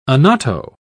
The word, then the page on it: annatto: British English